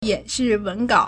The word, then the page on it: 演示文稿: yǎnshì wéngǎo